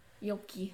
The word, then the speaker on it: yolky: Yolky. – Yolky. The yolky. L is silent.